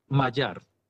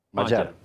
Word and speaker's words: Magyar: Magyar. – Magyar, vale.